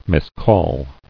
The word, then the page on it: Miscall: mis·call